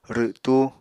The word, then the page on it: rwtú: Apatani